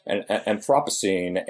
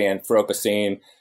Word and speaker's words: Anthropocene: It's funny because if you know, the British say Anthropocene, and some people say Anthropocene, some people say Anthropocene.